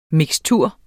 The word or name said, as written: mikstur